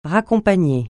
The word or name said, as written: raccompagner